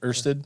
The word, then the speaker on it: Ørsted: So, from my Google box I have over here, so I wanted to know, how do you pronounce that O with the line? – Okay. – That is like in bird. – So it's like Ørsted?